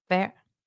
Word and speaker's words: Feher: I would imagine it's pronounced fair. It's F E H E R.